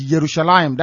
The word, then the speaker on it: Yerushalayim: If you go and say Jerusalem, most people won't understand you. The current pronunciation is Yerushalayim. That is Y, not J.